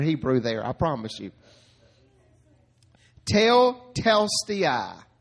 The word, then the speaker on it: tetelestai: Tetelestai. Say it. Tetelestai.